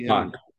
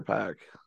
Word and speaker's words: Pac: Is it Pac or Pack? – I mean, it's whatever, it's Pack, but pronunciation is, inherent to one's own, so I'll say it however I want. – I just want to make sure I'm saying it right. Because some people will call him Pac. – I say Pac, I just think X-Pac, so I've always said Pac. – That's why I call him 1-2-3.